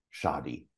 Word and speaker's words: Shahdi: he also serves as full time staff to his gifted cat, Shahdi.